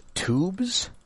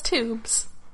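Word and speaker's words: Toubes: April 18th, 2015, written by Guy, I think this is pronounced Tubes. It's T-O-U-B-E-S. So, that's Tubes.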